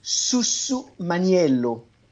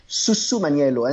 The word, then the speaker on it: Susumaniello: And so, and the grape variety is called Susumaniello. It's a nice way to pronounce it, a little bit difficult. I had to practice in front of the mirror this morning. Susumaniello.